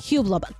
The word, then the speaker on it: Hublot: Hublot.